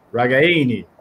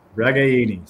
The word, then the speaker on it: Ragaini: It's Nico Ragaini. Think about it like you're in Italian. Ragaini.